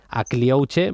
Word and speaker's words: Akliouche: Marco pronuncia muy bien, pero para los que lo queráis apuntar y demás, se escribe con K, Akliouche. Akliouche, Magnes Akliouche o Magn Akliouche, el jugador del Mónaco.